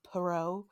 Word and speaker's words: Perrault: Because I think they were pronouncing him as like Charles Perrault. Or something like that. – Oh, I bet they were. – Yeah, like Foucault.